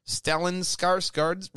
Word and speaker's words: Stellan Skarsgård: Talking to the Swedish news site Dagens Nyheter, Stellan Skarsgård revealed, Swedish. – What a name. – You know? Whew. Just take a minute to appreciate that. – I appreciate that you pronounced it correctly.